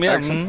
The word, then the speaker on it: Exum: You know, this may may surprise you as the guy I'm going to pick out but is it Axiom? Is that how you uh-huh. pronounce uh-huh. his name? Exum, yeah.